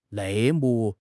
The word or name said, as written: 内幕